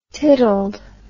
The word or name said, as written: tiddled